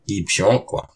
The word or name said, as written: Kipshôq